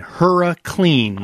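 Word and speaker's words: Hurriclean: One, in the tank, I want you to use a product called HuraClean. – How'd you spell it? – H-U-R-R-I clean. HuraClean. Oh. Like hurricane.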